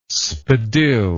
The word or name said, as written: spadille